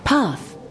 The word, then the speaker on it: path: Path Okay don't forget to pronounce th sound